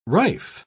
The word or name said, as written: rife